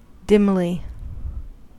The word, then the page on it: dimly: American English